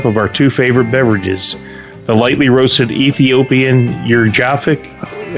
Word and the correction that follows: Yirgacheffe: Oh, man, I can't pronounce that. Yirgachevsk. Yirgacheffe.